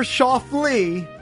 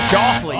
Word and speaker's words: Schauffele: Xander Schauffele. I want the first and last name. – Ooh. – Xander. – Right. – Schauffele. – Oh, he butchered Schauffele.